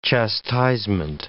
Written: chastisement

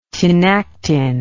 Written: tinactin

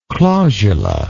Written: clausular

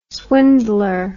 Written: swindler

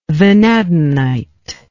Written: vanadinite

